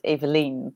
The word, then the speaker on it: Evelien: Actually, in Dutch, we would pronounce it as Evelien, but a lot of people, yeah, Evelien Van Es.